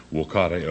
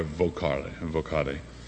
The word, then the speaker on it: vocare: The Latin verb that means to call is vocare, V-O, the Romans pronounce the V as a W, vocare or vocare, from which we get our word vocation or calling.